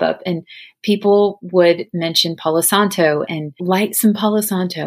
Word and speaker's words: Palo Santo: And I believe I'm saying that right. Palo Santo. That's how you say it.